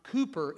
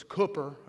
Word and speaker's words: Cooper: It's not Cooper, it's Cooper, okay, even though it's spelled K or C-O-O, it's not Coop, it's Coop, and again, that's because you've never been there that you don't know that, but he was at Klondike, little bitty country church.